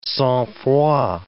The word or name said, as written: sangfroid